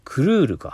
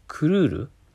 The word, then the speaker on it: Courir: これ何て言うのかなクルールか。クルール ?C-O-U-R-I-R。クルールって読むのかなだと思います。